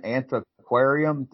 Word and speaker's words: Antiquarium: Antiquarium, does that sound right? – That sounds good.